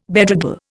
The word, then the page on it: bedraggle: Indian